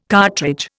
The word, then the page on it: cartridge: Indian